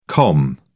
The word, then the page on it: com: British English